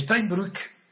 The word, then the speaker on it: Steinbrück: Pues bien, Steinbrück, ¿ha visto qué maravilla lo pronuncia? Steinbrück, no Brück, porque tiene y la U.